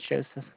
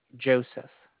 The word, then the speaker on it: Joseph: My name is Joseph, ma'am. – Josephine? – Joseph. Joseph.